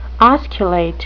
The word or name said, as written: osculate